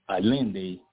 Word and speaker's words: Allende: And this is what Allende, some people, you know, pronounce it Allende, of course, it's spelled A-L-L-E-N-D-E.